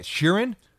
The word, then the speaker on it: Sheeran: Sheeran.